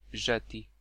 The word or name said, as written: fling